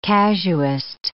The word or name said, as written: casuist